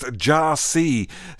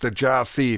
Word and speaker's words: Gyasi: His name is spelt Gyasi, G-Y-A-S-I, but it's taken from his new studio album, which is called pronounced Jarsi. So hopefully you understand that it's spelt one way, but pronounced Jarsi if you happen to be looking for that record.